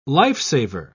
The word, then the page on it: lifesaver: British English